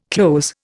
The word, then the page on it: cloze: Indian